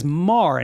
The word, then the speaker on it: Maher: My friend Steve, his last name is Maher. – Mm-hmm. M-A-H-E-R. – But Bill Maher spelled the same way. Maher. Mm. He says Mar, and it's spelled exactly the same way.